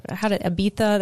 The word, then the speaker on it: Ibiza: Yeah, so I really want to go to Ibiza. Ibiza, that's how they say it.